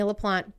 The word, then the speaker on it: LaPlante: And I'm saying LaPlante. It's spelled L-A-P-L-A-N-T-E. LaPlante? – LaPlante? Yeah.